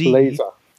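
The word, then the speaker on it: Blazr: Z, I said Z, but it's Z. Blazer. – Blazer. Blazer.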